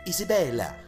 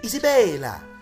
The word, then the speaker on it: Isabella: Get used to saying Isabella, Isabella. When you're in Italy, you have to say it like that.